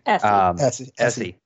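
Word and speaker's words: Essi: I think just his relationship with Issy, or what's her, sorry, I'm mispronouncing her name. Issa? – Essie.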